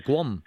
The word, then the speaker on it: Qom: Y hay otra que se llama Cuom, puede ser, ¿cómo se pronuncia? Q-O-M, Cuom. – Sí, sí, Cuom.